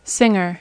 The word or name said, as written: singer